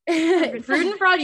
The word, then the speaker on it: Freudenfreude: She has like the Schadenfreude and Freudenfreude. – Freudenfreude, you said that right.